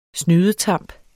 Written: SNYDETAMPS

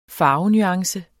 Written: farvenuance

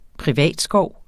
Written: privatskov

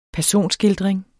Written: personskildring

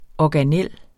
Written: organel

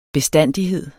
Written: bestandighed

